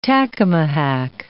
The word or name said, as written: tacamahac